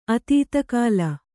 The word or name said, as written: ಅತೀತಕಾಲ